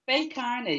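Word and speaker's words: Bé Carna: Is it Bay Carnet? Did I pronounce that correctly? – Faye Kearney, you did.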